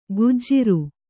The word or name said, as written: ಗುಜ್ಜಿರು